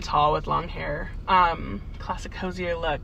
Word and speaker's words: Hozier: Hozier. – I always say Hozier because I feel like it's like French or some shit. But yeah, Hozier.